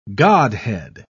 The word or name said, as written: godhead